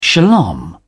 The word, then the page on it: shalom: British English